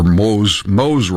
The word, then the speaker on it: Moises: Moises Rodriguez, or Moes Rodriguez, I guess it's pronounced, Moes Rodriguez, M-O-I-S-E-S Rodriguez.